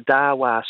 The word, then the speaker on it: Dahua: Have you ever visited a Dawa store? – I was just about to. – Okay, so that's spelled D-A-H-U-A.